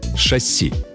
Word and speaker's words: шасси: Шасси. Ударение в этом слове только на последний слог.